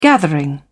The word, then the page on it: gathering: British English